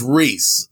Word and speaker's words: Rhys: but it's not Rice. It's pronounced Reese, R-H-Y-S.